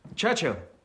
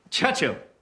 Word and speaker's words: Churchill: Churchill, that's how you say it. All together like that. Churchill. Oh, well, that explains a lot. You see, I'd heard church and hill, but couldn't find either.